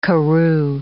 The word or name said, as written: karroo